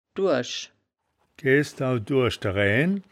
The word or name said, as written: duasch-durisch